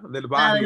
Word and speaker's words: Del Barrio: Paquita del Barrio. – Del Barrio, yeah, you said it right.